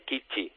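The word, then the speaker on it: Kitchee: ¿Me has dicho que se dice Kiche o cómo se dice? – No, con I, Kichi. Aunque tenga dos vale. – Es, sí. – Vale, como en inglés, ¿no? – Kichi. Kichi. Kichi.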